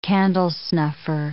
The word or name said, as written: candlesnuffer